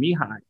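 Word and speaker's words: Mihaly: And he pronounces his first name Mihai, even though there's an L in there.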